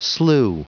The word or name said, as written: slew